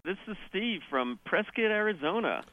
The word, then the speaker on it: Prescott: It's Prescott, as in biscuit.